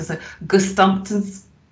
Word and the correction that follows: Gesamtkunstwerk: Work. – Oh, Gesamtkunstwerk. – Yeah, Gesamtkunstwerk. I think that applies to the Bowie project.